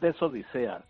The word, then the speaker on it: desodisea: Es mucho más fácil de pronunciar, es desodisea. Lo contrario de la odisea es la desodisea.